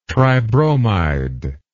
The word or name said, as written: tribromide